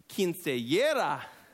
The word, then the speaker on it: Quinceañera: All right, who's been to Quinceañera? A what? Did I say it wrong? Jesse, help me out. Quinceañera.